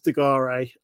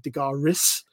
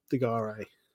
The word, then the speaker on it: De Garis: And yeah, you got it right. It's De Garis. A lot of them were like De Garis. It was just like, no, it's not pronounced like that. De Garis.